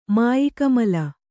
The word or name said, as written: ಮಾಯಿಕಮಲ